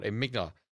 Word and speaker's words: amygdala: Enigmala, isn't it? It's called amygdala.